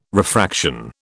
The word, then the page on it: refraction: British English